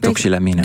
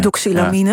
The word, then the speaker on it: doxylamine: Doxylamine. – Doxylamine.